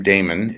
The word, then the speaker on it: Daamen: My first name, Damon, is spelled D-A-A-M-E-N, uh-huh.